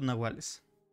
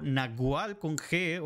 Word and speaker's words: Nahual: Se escribe N-A-H-U-A-L, pero a veces este, se, percató como Nahualí o Nahual con G o incluso con W se ha podido decir,